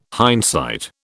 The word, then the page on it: hindsight: British English